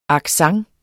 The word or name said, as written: accent